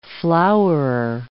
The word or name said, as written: flowerer